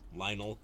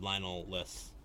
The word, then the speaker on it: Lionel: Like, if you were Lionel, how did I say his name weird before? Hollins. – Lionel. You said Lionel-less.